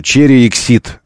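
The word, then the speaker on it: Chery Exeed: Мы говорим про Черексид. Это в одно слово произносится все в России.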